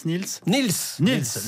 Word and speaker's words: Niels: Alors on prononce Niels. – Niels, le livre de l'invité surprise du prix Goncourt.